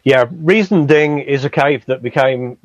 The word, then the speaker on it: Riesending: Riesending.